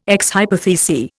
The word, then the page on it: ex hypothesi: Indian